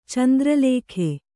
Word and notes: ಚಂದ್ರಲೇಖೆ: candra lēkhe

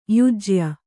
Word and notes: ಯಾಜ್ಯ: yājya

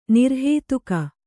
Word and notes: ನಿರ್ಹೇತುಕ: Kannada

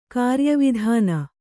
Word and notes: ಕಾರ್ಯವಿಧಾನ: kannada

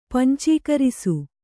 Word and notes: ಪಂಚೀಕರಿಸು: pancīkarisu

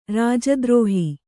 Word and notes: ರಾಜದ್ರೋಹ: rāja drōha

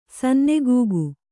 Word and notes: ಸನ್ನೆಗೂಗು: kannada

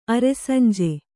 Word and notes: ಅರೆಸಂಜೆ: kannada

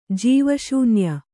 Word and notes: ಜೀವಶೂನ್ಯ: jīva śunya

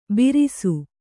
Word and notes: ಬೀರಿಸು: kannada

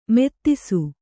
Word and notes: ಮದಿಸು: madisu

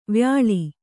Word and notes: ವ್ಯಾಲ: vyāla

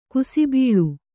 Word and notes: ಕುಸುಬಲು: kusubalu

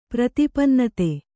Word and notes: ಪ್ರತಿಪನ್ನತೆ: pratipannate

